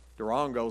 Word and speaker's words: Durango: Durango is how they say it. It's, we say Durango.